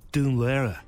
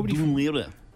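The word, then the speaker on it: Dún Laoghaire: But on RTE, they call it Dunlera. – That drives me mad. – It drives me. – Dunlera. – Nobody in Dunleary calls it Dunleary. Calls it Dunlera. Nobody.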